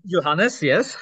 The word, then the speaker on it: Johannes: So is Johannes? Is that how I say your name? Johannes, yes.